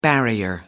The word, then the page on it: barrier: American English